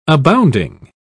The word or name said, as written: abounding